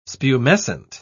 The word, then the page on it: spumescent: British English